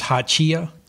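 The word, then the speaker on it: Hachiya: First thing is come up on a pronunciation. This is haichia.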